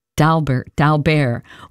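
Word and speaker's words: d'Albert: Music now by a composer that is not a household name, Eugen Daubert.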